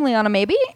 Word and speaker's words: Liana Maeby: I'm on Twitter at my name, Liana Maeby, and Instagram.